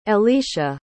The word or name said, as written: Ellisha